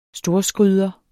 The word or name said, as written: storskryder